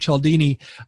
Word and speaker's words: Cialdini: And so if you're listening to this and you're not familiar with Robert Cialdini, Google him. He spells his name a little bit differently. It's C-I-A-L-D-I-N-I. C-I-A-L-D-I-N-A. It's pronounced Cialdini, Robert Cialdini.